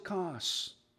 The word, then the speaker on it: Cos: Some people may pronounce it Kos. I have a map here, and many of you have maps in your Bibles. I would encourage you to go ahead and find, it's spelled C-O-S, on your maps there, so that we can enjoy this journey.